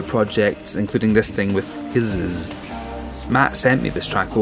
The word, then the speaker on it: Kiszes: I'm pronouncing that weird because it's spelt weird. K-I-S-Z-E-S. Kizzes.